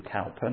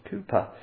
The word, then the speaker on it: Cowper: And he was very pleased because I now know that we should pronounce it William Cowper, not Cooper.